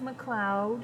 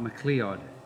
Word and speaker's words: MacLeod: It's pronounced MacLeod. – Mm-hmm. Not MacLeod.